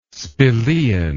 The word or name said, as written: spelaean